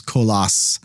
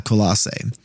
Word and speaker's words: Colossae: A lot of people will pronounce this Koloss. I believe the actual modern Greek name, if you were Greek or you're in Greece, they're going to say that the city is Koloss, not Colossae.